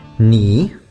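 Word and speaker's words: knee: Ни. – Да, и тоже пишется таким же странным образом, с кей впереди, который не считается.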